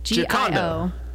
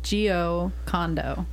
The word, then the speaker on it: Giocondo: Giocondo. – Giocondo. Condo like a condo. – Like what? – Like a condo where you live.